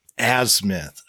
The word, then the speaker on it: azimuth: Like shooting an azimuth with a compass. – Okay. I looked up how to say it, and I couldn't quite get a good, you know, read on it. So, azimuth. Gotcha.